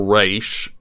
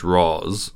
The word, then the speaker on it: Ra's: In the early minutes of each movie in the trilogy, the main villains, Ra's al Ghul, or Ra's, is apparently how you pronounce it, but in the Dark Knight trilogy, it's Ra's.